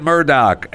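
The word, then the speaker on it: Murdaugh: He did say he was saying Murdoch, and apparently that's how the people in South Carolina pronounce his name.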